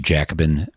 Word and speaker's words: Jacobin: uh, they can do that through Jacobin Magazine.